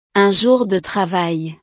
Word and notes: un jour de travail: frances

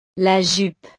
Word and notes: la jupe: French

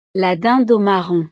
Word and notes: la dinde aux marrons: frances

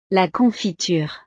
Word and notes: la confiture: frances

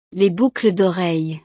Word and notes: les boucles doreilles: frances

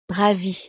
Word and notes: ravi: frances

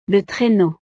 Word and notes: le traineau: frances

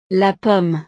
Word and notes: la pomme: frances

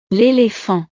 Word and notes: l'elephant: frances

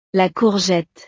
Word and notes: la courgette: frances

